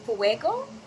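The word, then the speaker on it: Fwego: Oh yeah, I go by Fuego fuego.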